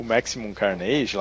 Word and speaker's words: Maximum Carnage: É, quando a gente tava jo jogando para gravar o, o Maximum Carnage lá.